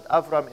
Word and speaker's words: Abram: You pronounce it Avram in my language.